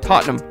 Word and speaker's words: Tottenham: So I spell it Cheltenham, C-H-E-L-T-N-U-M. If you spell it like that, you can say it correctly. It's just like Tottenham. You know, it's not Tottenham. You just spell it T-O-T-N-U-M, Cheltenham.